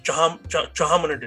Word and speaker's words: Chauhan: So what are the origins of this particular dynasty, Chauhan dynasty? – So the dynasty's name is Chauhan. That's the original word, and again, as part of apabhransh, it became Chauhan.